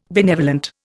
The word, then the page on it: benevolent: Indian